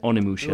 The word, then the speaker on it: Onimusha: Onimusha? Is that how you pronounce it? – Onimusha.